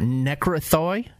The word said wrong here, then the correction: Necrothay: What was it? – It's Necrothay.